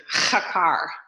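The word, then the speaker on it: chakar (חקר): So we see that the word search is, and Justin told me how to pronounce this, you have to say chakar.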